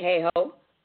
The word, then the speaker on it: Hayhoe: It's Catherine H-A-Y-H-O-E. – Okay, now following. I would say hey-ho.